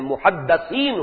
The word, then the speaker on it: محدث: اس لفظ کو اچھی طرح صحیح تلفظ کے ساتھ سمجھ لیجئے، محدث دال کے زیر کے ساتھ، وہ تو وہ علماء ہیں کہ جو علم حدیث کے ماہر ہیں، محدثین، فقہاء و محدثین، دال کی زیر لگائیے.